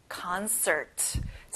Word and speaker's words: concert: 공연, 연주회, concert. 자, con에 강세를 두고요. 끝에 R 발음까지 정확하게 해보세요.